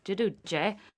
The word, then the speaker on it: Giudice: I know they say Judas, but I like the real pronunciation. I think it's sing song. It's poetic. Jidu-jay.